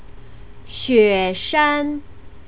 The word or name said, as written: xueshan